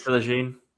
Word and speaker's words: Philogene: Well, I mean, if we can keep the likes of, what is it, Connolly, Delap, Toufan, and if, what's his, Philogene, is that how you say it? – Jaden Philogene. – Yeah, if he's not playing, then maybe we'll nick a 0-0.